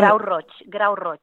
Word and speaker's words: Grau Roig: Vous allez me reprendre. Gros Roig, Sol de U, El Tarter, Canillo et Encamp. Est-ce qu'il y a... – Et Grau Roig.